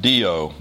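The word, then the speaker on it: dio (διό): The verse begins with a little Greek word pronounced dio, D-I-O.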